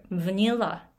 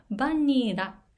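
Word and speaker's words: vanilla: で、 v ー、ニーを強く、 v ニラ、 v ニラっていうふうにして発音すると、バニラって通じなかったのが、 v ニラにすることによって、一気に通じるようになります。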